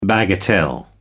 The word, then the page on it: bagatelle: American English